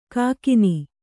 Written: ಕೆಕ್ಕಾನೆ

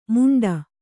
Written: ಮುಂಡ

